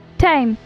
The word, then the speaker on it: Thames: Tame. – I dig it. Spell that for the listening audience and Bradley. – T-H-A-M-E-S. Like the Thames River.